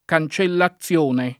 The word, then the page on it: cancellazione: lingua italiana